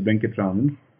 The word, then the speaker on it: Venkatraman: And my last name is Venkatraman.